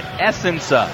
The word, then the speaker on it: Essenza: Bob is the Essenza of our existence. – And that's what you explained to me how to say it, Essenza.